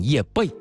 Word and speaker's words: 叶蓓: 这个首先跟大家说一下，这个字呢只有一个读音，念叶蓓啊。